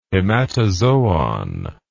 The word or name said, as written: hematozoon